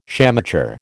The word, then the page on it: shamateur: American English